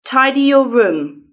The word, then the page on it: tidy your room: British English